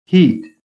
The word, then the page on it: HEAT: American English